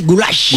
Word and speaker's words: goulash: Es el goulash es pronunciado así, goulash.